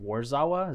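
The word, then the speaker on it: Warszawa: and I think it's pronounced Warzawa.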